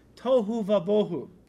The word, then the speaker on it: tohu vabohu: The phrase, guys, without form and void in Hebrew is tohu vabohu.